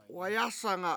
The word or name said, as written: wayAsanga